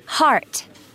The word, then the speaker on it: Hart: Hart. – Spell that, please. – H-A-R-T.